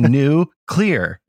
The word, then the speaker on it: nuclear: So nuclear. Just say new clear.